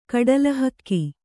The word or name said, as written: ಕಡಲಹಕ್ಕಿ